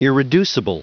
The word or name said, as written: irreducible